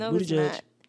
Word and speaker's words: Buttigieg: His name is Buttigieg. It's Buttigieg. Pete Buttigieg.